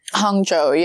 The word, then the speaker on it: Hangzhou: Is that how you pronounce it? – Hangzhou, yeah. Hangzhou, okay.